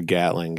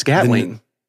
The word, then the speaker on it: Gatling: You mentioned the Gatling gun. – Yeah. – You pronounced it like many people do, as Gatlin. And it's Gatling. – Right, with a G on it.